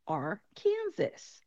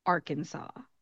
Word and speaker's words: Arkansas: Looking at the spelling, you would think that it would be Arkansas. We already know how to pronounce Kansas, so that makes sense. We're just going to call it Arkansas. Unfortunately, it is not that easy. Instead of being Arkansas, this state is called Arkansas.